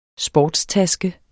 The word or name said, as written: SPORTSTASKER